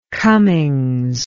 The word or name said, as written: cummings